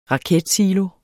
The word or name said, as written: raketsilo